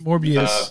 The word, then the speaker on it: Morbius: No, Morbius with a B. – No, Morpheus with a P. – No, just say it correctly, the way that people know it's said. – It's Morbius, but anyway.